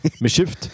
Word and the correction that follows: mischief: oder wie heißt das? – Mischief. – Mischief. Brt, Mischief. Ist schwierig.